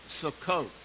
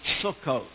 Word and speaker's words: sukkot: Older Jewish people, not that anybody here is older, but older Jewish people in America pronounce it sukkos. And until the nation Israel, they pronounce that last letter, what we call tav, as an S.